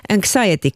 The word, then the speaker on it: anxiety: Anxiety Club. – Okay. – Hur uttalar man anxiety? – Som du gör det.